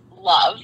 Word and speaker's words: LOVB: Yeah, so League One volleyball is actually pronounced love. – Mm-hmm. – So it's kind of a silent B.